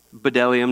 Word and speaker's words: bdellium: And some of you are saying there's a B there, but you don't pronounce the B. Bdellium, no, it's bdellium and onyx stones, which are there.